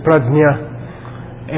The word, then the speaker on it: Prajna: The real pronunciation in Sanskrit is Prajna. And the znya part came into the Western languages, like in Russian, znyayu, which means to know.